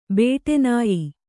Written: ಬೇಟೆನಾಯಿ

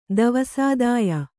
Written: ದವಸಾದಾಯ

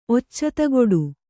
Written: ಒಚ್ಚತಗೊಡು

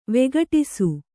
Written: ವಿಘಟಿಸು

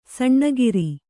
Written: ಸೋನಗಾರ

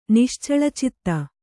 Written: ನಿಶ್ಚಳಚಿತ್ತ

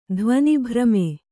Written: ಧ್ವನಿಭ್ರಮೆ

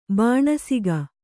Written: ಬಾಣಸಿಗ

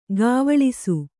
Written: ಗಾವಳಿಸು

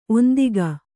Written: ಒಂದಿಕೆ

